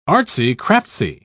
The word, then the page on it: artsy-craftsy: British English